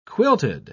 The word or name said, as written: quilted